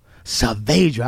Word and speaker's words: Saavedra: Saavedra.